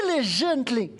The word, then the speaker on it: diligently: For he that cometh to God must believe that he is, and that he is a rewarder of them that diligently. Did I say it right? No? Yes, pretty good. Diligently. Sometimes I put the accent at the wrong place and made my wife smile.